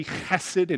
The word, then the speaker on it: hesed: And then if you actually pronounce the Hebrew correctly, hesed and hen, you won't spit on the person next to you.